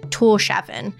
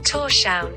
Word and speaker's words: Tórshavn: For example, the capital city is called, phonetically, we would call it Tórshavn, but this is the way it's said. – Tórshavn. Tórshavn.